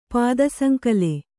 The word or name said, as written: ಪಾದಸಂಕಲೆ